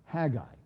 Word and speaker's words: Haggai: By the way, his name is pronounced Haggai, okay, just so you know.